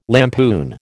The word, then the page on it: lampoon: American English